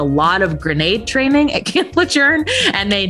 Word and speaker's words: Camp Lejeune: First of all, no one tells you that it's pronounced Camp Lejeune. You can tell usually who has been stationed at Camp Lejeune by the way they say it, because the way it's spelled, it looks like it's Camp Lejeune. And apparently it's named after someone whose name was pronounced Lejeune. And so that's why it you need to pronounce it that way.